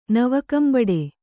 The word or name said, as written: ನವಕಂಬಡೆ